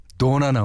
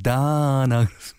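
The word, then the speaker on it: Donna: 음, 최현신 씨가 도나 나올 줄 알았는데 도나. 근데요, 사실은 오, 다나. 다나라고 발음하지. 예.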